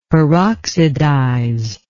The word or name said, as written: peroxidize